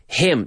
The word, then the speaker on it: hymn: Hymn. That's a hymn. Hymn. H-Y-M-N. H-Y-M-N. It's strange. It's silent N, people. Hymn. But then you have H-I-M, him, él.